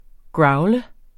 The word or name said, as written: growle